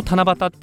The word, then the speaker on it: 七夕: もともと日本の神事であった七夕、この七夕っていうのが、棚が、木偏に月を 2 個書く棚で、もう一つの機械の木って書いて七夕って読むんですね。